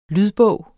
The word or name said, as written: lydbog